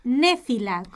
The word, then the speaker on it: Néfila: Vamos a decirla despacito. Néfila, como suena. – Con acento en la E.